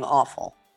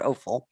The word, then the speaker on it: offal: For those that don't know what offal is, it's O-F-F-A-L. – It's awful. – And he was actually pronouncing it incorrectly. He was saying awful, and it's not awful. It's offal.